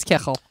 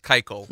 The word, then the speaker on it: kichel: Every Jewish mother yesterday was looking, if they were watching the screen like I was, and you're breaking your teeth on it, it's Kichel. – So it's pronounced Keichel. – No, it's really not.